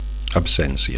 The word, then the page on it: absencia: Catalan